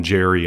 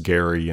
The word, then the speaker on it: Geri: I like to call them Jerry and Freaky, by the way, just to entertain myself as a terrible pronunciation that sounds funnier in the modern era, Jerry and Freaky, but I think it's more correctly pronounced Geri and Freki.